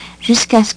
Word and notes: jusqu'a ce: French

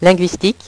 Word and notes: linguistique: French

